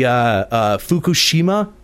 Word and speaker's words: Fukushima: the Fukushima. Is that how it's pronounced? – Yeah, the Fukushima nuclear power plant.